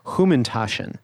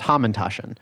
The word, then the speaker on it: Hamantashen: I think Fleischman says he pronounces Humantashen, which is like a traditional Jewish cookie for the holiday of Purim. Mm-hmm. Though I've always heard it pronounced Hamantashen.